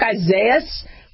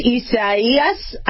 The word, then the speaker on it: Isaias: It looks like Isaias, but it's not. It's pronounced Isaias.